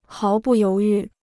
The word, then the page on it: 毫不犹豫: háo bù yóu yù